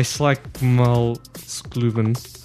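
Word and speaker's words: Slagsmålsklubben: Let me have a look at this. Slagsmalskuben. – Yep, very good.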